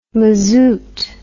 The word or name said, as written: mazut